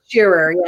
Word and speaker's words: Shearer: Shearer, yes, close.